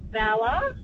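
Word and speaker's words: Valla: I'm just driving past Vala and I thought I should call in and say it's pronounced Vala and Urunga and Ginungay Way.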